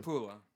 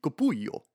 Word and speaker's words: Capullo: Is it Caputo or Capula? I'm gonna mispronounce the name too. – Capula. – Capula with an L. – Yes, two L's. – So Capullo, or it could be Capullo.